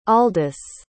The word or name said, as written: aldous